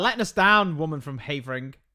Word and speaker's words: Havering: Havering. How'd you spell it? – H-A-V-E-R-I-N-G.